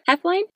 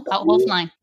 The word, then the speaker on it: Hoeflein: Is it Hoeflein? – Hoeflein. – Hoeflein. – Hoeflein. No, Hoeflein with a long O. So Hoeflein.